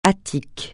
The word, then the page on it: Attique: francais